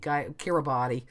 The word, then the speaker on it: Kiribati: It's spelled K-I-R-I-B-A-T-I. And again, not 100% sure, but I think it's Kiribati. – Yes.